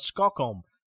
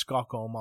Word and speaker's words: Skokholm: Skokholm. Sorry, I pronounced it wrong. It's Skokholm. I thought it was Stockholm, Sweden.